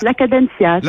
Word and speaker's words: La Cadencia: Vous êtes la présidente de La Déjà, est-ce que je le prononce bien Voilà, avec un petit peu plus de légèreté ah, peut-être. La Cadencia.